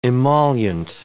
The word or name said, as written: emollient